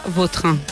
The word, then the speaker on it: Vautrin: Es que a mí me suena mejor Vautrin. Es como... – Da más fuerza, ¿no? – Más fuerza, sí, más la pronunciación. – Sí, elegante. – La original francesa, Vautrin.